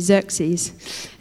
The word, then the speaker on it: Xerxes: So cast your mind back to the Persian Empire about 500 years before Jesus was born, and enter King Xerxes. I'm hoping that's how you say it. That's what Chris told me, Xerxes.